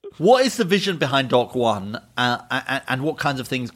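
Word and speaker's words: Dokk1: Pronounce the name of the library. – Dock One. – Dock One Library. – You could have got that. – I know. – D-O-K-K.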